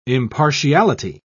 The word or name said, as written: impartiality